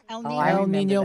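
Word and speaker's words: El Niño: El Nino, La Nina.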